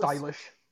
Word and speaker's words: Eilish: I think it's Eilish. – It's pronounced Eilish.